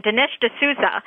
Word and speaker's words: Dinesh D'Souza: Now, this is now pronunciation. I've been all over the internet trying to get the correct pronunciation. Dinesh D'Souza.